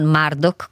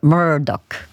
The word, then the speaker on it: Murdoch: Comunque Murdoch, così si pronuncia, e quindi spero di ricordarmelo per le prossime volte, perciò grazie a chi me l'ha segnalato.